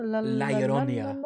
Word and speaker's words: La Llorona: Is that how you say it? – La Llorona. – There we go. – Just like that.